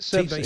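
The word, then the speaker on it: Tebay: T-Bay, is that how you pronounce it? – T-Bay Service Station.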